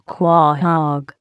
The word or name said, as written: quahog